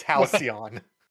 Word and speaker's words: Halcyon: You just horribly mispronounced it. – Oh, did I? Okay. – It's Halcyon.